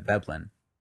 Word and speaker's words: Veblen: But it's a V. – Yeah, Veblen. – I don't know why when you pronounce it, it doesn't sound like a V, but it doesn't sound like a B either. But it's like somewhere in between. It's like a THB, like Veblen.